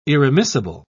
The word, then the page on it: irremissible: British English